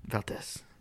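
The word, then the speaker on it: Valdes: By the way, the Valdez is spelled with an S, and it bothers me. – Yeah. – It's Valdez. – Valdez.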